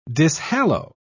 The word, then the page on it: dishallow: British English